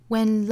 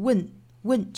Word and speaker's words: when: When life gets you down, 注意第一个词就是 W-H-E-N. 这个词很多同学会把它读成 win 这样的音。其实这个音呢，里面的这个 a 在我们汉语里也是找不到对应的，所以我们读的时候要格外注意，先找好元音 a 的口型，这个核心找好，再去加前后辅音 a。